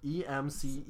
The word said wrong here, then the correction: emcee: MC like MC. Not what? How did you say it? – EMC. – It's MC.